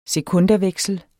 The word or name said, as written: sekundaveksel